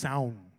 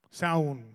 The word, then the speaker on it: Samhain: But I think it's pronounced Saun. Saun, right? Saun.